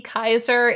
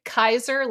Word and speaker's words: Kaiser: So you can search for Amanda Lea Kaiser, and my middle name is spelled weird. It's spelled L-E-A. So Amanda L-E-A Kaiser, like the role, K-A-I-S-E-R.